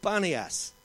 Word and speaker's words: Banias: So it's now called Banias, B-A-N-I-A-S.